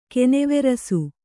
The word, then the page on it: ಕನವರಸು: Kannada